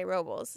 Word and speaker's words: Robles: Well, the locals say Robles, so um, my Lily's nanny is from there.